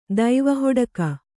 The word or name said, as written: ದೈವಹೊಡಕ